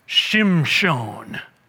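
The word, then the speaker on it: Shimshon (שמשון): And so, Mrs. Manoah, verse 24, has a son, and they call his name Samson, which means little son, S-U-N. I love the Hebrew pronunciation of Samson, Shimshon.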